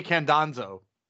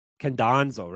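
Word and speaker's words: Khandozhko: Yeah, it's Sergey Candanzo. – Candanzo, right, that's it. Like Gonzo.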